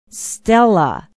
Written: stella

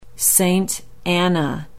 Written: saint anna

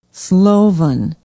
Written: Slovan

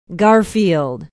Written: Garfield

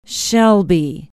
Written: shelby

